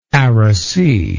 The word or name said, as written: arac